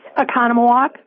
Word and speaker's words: Oconomowoc: Oconomowoc.